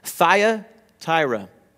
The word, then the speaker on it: Thyatira: But to this church, there's a church in Thyatira that we're going to be talking about today, which is a fun word to say, Thyatira. I looked it up on Google to make sure I was pronouncing it correctly.